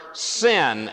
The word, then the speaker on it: Sin: You will see the word sin, S-I-N, and actually we will pronounce that this morning, sin, because that's the appropriate Hebrew pronunciation of the word.